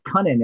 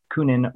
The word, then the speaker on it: Kunin: Luke, yeah, it's Cunning. It, it's a very strange name or uh, pronunciation. I, I would have, I would have said Kunin or something like that. But yeah, Cunning.